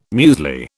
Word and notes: muesli: British English

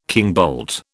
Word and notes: kingbolt: British English

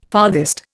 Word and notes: farthest: Indian

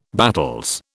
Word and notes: battels: British English

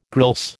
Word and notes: grilse: American English

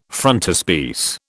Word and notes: frontispiece: British English

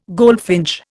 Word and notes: goldfinch: Indian

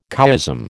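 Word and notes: Couéism: American English

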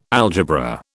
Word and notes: algebra: British English

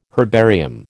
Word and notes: herbarium: American English

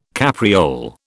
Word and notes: capriole: British English

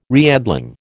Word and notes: reedling: American English

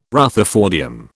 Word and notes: rutherfordium: British English

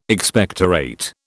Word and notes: Expectorate: British English

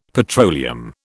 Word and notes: petroleum: British English

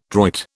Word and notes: droit: British English

